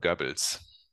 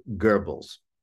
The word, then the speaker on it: Goebbels: So would you pronounce it correctly for me once? – Joseph Goebbels. – Joseph Goebbels. – That's right.